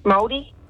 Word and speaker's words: Māori: Māori? – Oh.